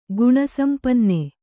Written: ಗುಣಸಂಪನ್ನೆ

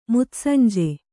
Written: ಮುತ್ಸಂಜೆ

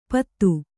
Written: ಪತ್ತು

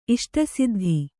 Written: ಇಷ್ಟಸಿದ್ಧಿ

